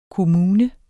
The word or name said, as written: kommune